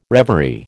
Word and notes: reverie: American English

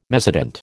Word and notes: mezzotint: American English

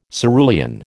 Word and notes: cerulean: American English

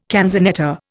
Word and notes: canzonetta: Indian

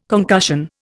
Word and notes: concussion: Indian

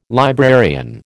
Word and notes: librarian: American English

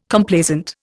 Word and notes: Complaisant: Indian

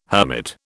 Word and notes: hermit: British English

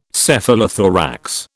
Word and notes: Cephalothorax: British English

